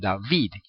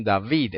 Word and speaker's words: David: Por eso en Israel, por ejemplo, usted oye el nombre de David y todos dicen David. Pero en Temán, en el Yemen, los judíos del Yemen no dicen David, ellos dicen David.